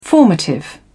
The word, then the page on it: formative: British English